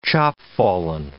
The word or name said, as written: chopfallen